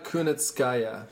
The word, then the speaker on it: Kunitskaya: Yana Kunitskaya. – Nice pronunciation there.